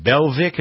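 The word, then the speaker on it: Belviq: The FDA has okayed something called, is it Belvic? Is that how you pronounce it? – Yes.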